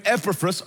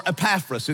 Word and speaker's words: Epaphras: It was started by a man named Epaphras or Epaphras. It's however you say his name, both are correct, really.